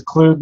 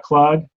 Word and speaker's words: Klug: My last name is Klug, not Klug, but that's all right.